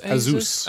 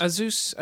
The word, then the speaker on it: Asus: how do you say it? Asus? – It's Asus. – Asus, okay.